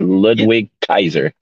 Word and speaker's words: Ludwig Kaiser: Ludwig Kaiser. That's it.